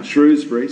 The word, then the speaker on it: Shrewsbury: How a young man from Shrewsbury, because that's how I know how it's said now, Shrewsbury, so out there, is a Manchester United supporter, and here in Colombia.